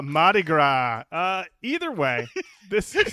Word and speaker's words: Mardi Gras: This movie's based around Mardi Gras. Am I saying that right? – You are now. – Oh boy. – Mardi Gras? – Mardi Gras. I think I missed the R the first time.